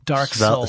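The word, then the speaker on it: Dark Seoul: Dark Soul. – Spelled like the capital of South Korea.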